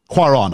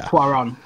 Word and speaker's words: Cuarón: Cuarón, I would say, yeah. – Cuarón.